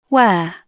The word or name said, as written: wear